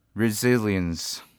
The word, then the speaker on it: resilience: Our second tip focuses on another word in the sentence, which is resilience. This word has five syllables, and the stress should be on the second Z sound. Although it is spelled as S-I, it should be pronounced as Z-I, Z, instead. Also notice that the second half, L-I-E-N-C-E, should be pronounced articulately, syllable by syllable, as li-ns, not li-ns.